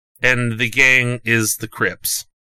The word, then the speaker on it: Crips: Is it Crips or Crips? Is there a T in there? – No, or a Y. – No. Crips. – Oh. How do you spell it? It's just C R I P S. – With it, yeah, and then the S at the end.